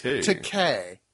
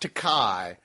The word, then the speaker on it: Takei: And it's Decay, which rhymes with gay, not Takai, which rhymes with that other guy.